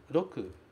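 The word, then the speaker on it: roku (六): Cuidado com esse R, porque a pronúncia do R no japonês é sempre rarirurero. Roku. Roku. Se você pronunciar roku, o japonês não vai entender o que você está dizendo.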